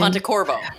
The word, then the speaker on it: Pontecorvo: You know, you can't, it's tangential, but you can't, Battle of Algiers was the film, Carlo Pontevecchio? Do I say it right? – His name? Pontecorvo. – Pontecorvo. What am I saying? Yeah, Pontecorvo. I mean, there was a different time.